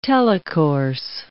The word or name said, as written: telecourse